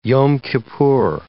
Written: Yom Kippur

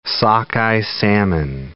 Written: sockeye salmon

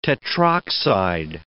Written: tetroxide

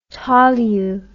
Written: tolu

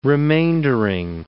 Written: remaindering

